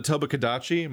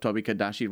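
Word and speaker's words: Tobi-Kadachi: For anybody trying to look up Tobakadachi right now, it's Toby, not Tobo.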